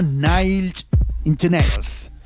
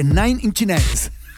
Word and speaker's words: Nine Inch Nails: Viernes libre y suena Nine Inch Nails. Lo dije mal, ¿eh? Porque Nine Inch Nails.